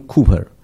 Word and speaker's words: Cowper: 这首诗歌的作者 William Cooper 这样读，它其实拼成 C-O-W-P-E-R，有的人会把它的这个读音读得不一定很正确，他读的 Cooper C-O-W-P-E-R William Cooper。